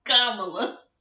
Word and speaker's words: Kamala: Kamala. – Kamala Harris, yeah. – Why do I need Joe Biden? – I don't know. That's I was in the midst of saying Kamala when you asked.